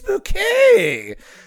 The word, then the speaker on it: Bucket: But see, her name is Hyacinth Bouquet, but her name is spelled Bucket, and they're always the mailman, the postman. He's always delivering mail. It's like, is Mrs. Bucket here? She's like, it's Bouquet. She